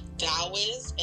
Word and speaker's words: Taoist: Taoist, and Taoist is spelled with a T, but it sounds like a D. T-A-O-I-S-T.